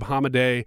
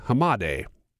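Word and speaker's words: Hamadeh: This is coming out of Arizona, and honestly, it has nothing to do with Carrie Lake or Blake Masters or Abe Hamade. I pronounced his name wrong. Hamade.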